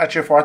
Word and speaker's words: Ejiofor: Chiwetel Ejiofor.